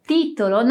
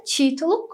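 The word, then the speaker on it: titolo: Então, a palavra título não pode ser pronunciada como a palavra título, como muitos dizem, ok? Então, repete aí. Título.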